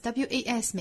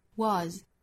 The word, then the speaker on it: was: W a s 美音发音的口型更大，was was 更大更松。